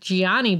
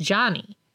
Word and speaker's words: Gianni: It's spelled Gianni. – But his name is Giovanni. – Giovanni. – And it's shortened to Johnny. – It looks like Gianni, but it's pronounced Johnny.